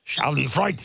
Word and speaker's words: Schadenfreude: Schadenfreude, not shot, not Marty. – What did I say? – Hard shot. You said schadenfreude. – How should I pronounce it? – Schadenfreude. – Schadenfreude. – Schadenfreude. – I concede to my German co-host to the right.